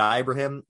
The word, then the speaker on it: Ibrahim: They played hard, losing Muhammad Ibrahim, which, by the way, I just learned that this week that it's pronounced Ibrahim. I swore that it was Ibrahim because that would be how you would normally say it.